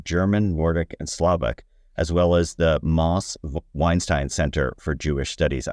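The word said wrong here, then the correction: Mosse: It's counterintuitive. It's Mossy Weinstein. – Okay, Mossy Weinstein.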